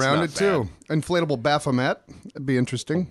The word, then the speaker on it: Baphomet: I always pronounce it Baphomet. It's Baphomet. – I've never even heard it before. – I've seen it. I've seen the name, yeah. – You've seen it. – Baphomet. – Yeah. – Okay. – Yeah. – That's the correct pronunciation of it.